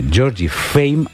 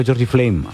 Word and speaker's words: Georgie Fame: Pensemos, Felipe, Georgie Fame and the Blue Flames. Sí, siempre digo Georgie Flame and the Blue Flames, pero bueno, es Georgie Fame and the Blue Flames.